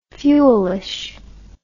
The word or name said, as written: fuelishly